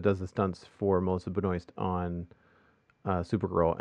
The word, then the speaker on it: Benoist: And am I pronouncing Melissa's last name correctly? – Yes. – Okay. Because, like, so in Canada, the Canadian in me wants to say Benoit or Benoit, you know, Benoit without the S.